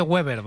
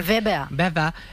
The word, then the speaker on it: Weber: Está Manfred Weber, que se dice sí, Weber, sí. No se dice Weber, ¿vale? – Sí, sí. – Se dice Manfred Weber. – Weber.